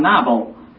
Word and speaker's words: Nabal: Denk maar aan die naam Nabal. – Wordt die B uitgesproken als een V? – Ja, die B spreekt uit als een V.